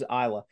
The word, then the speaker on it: Isla: Isla. Uh, oh, it's Isla.